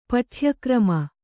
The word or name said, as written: ಪಠ್ಯಕ್ರಮ